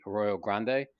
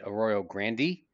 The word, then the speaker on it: Arroyo Grande: Arroyo Grande, which Spanish pronunciation would be Arroyo Grande. I've heard an adult male say Arroyo Grande. there's Paso Robles. R-O-B-L-E-S.